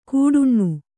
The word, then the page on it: ಕೂಡುಣ್ಣು: kūḍuṇṇu